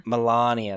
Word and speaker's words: Melania: And also Melania, if that's how you say her name, Melania.